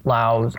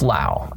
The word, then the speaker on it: Lao: so the French called it Laos. I don't know. So it's very complicated, but I believe it's Lao. That, as far as I can tell, it's pronounced Lao.